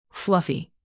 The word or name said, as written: fluffy